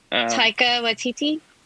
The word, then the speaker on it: Taika Waititi: Taika Waititi? – Thank you.